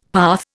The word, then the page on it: path: Indian